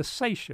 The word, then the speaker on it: seiche: Oceanographers also talk of another thing that most people have trouble getting a hold of, the Seiche effect. Think of it as the slosh. – Spell that. Can you spell it? – S-E-I-C-H-E. – S-E-I-C-H-E, the Seiche effect.